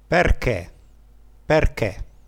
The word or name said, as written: perche